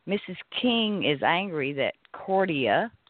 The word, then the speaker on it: Cordia: I am a graduate of Cordelia School. I hope I said that right. – The way it's spelled. It's pronounced the way it's C-O-R-D-I-A. Cordia.